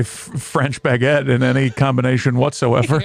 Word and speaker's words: baguette: But everyone did walk around with the baguettes, those big-ass breads.